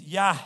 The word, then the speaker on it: Yah: So when I think about God's name and how it says grace, grace twice, it's almost like let me see if I can pronounce it. It's Yah. We say Yahweh, but it's really Yah.